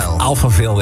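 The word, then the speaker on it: Alphaville: Ja, hoe spreek je dit uit? – Opa Veil. – Alpha Veil, ja, wat je wil.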